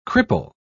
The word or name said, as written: cripple